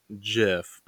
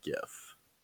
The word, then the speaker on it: GIF: It's called AMA Confessions, and it is so their GIF game is incredible. – Oh wow. – Is it GIF or JIF? – Well, it's supposed to be JIF, but everyone pronounces it GIF.